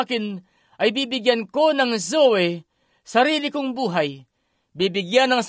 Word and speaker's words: Zoe: And the both Greek words as the life of God or eternal life is pronounced Zoe, Z-O-E, Zoe life.